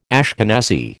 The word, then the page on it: Ashkenazi: American English